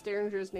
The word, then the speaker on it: Dareinger: Is it not Derringer? – It's Dareinger. It's, I mean, it's however you want to pronounce it, but I.